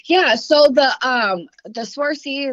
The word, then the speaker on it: Swarovski: And I know from experience that there's a huge difference between the Swarovski, I'm probably not pronouncing that very well, but Swarovski.